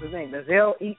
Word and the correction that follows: Ezekiel: or something like that. But Ezekiel, know, if y'all listen, they call him Ezekiel.